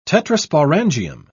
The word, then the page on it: tetrasporangium: British English